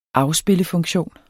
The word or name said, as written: afspillefunktion